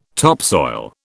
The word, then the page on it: topsoil: British English